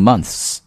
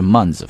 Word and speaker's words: month's: 那么在朗读的时候注意一下这个 months 啊，不要把它读成 months 啊，直接就是 months 发 z 这个声音就好了啊，month's election。